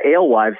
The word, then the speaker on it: alewife: Yeah, alewife.